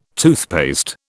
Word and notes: toothpaste: British English

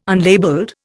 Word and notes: unlabelled: Indian

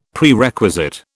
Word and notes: Prerequisite: British English

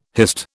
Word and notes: hist: British English

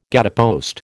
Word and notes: gatepost: American English

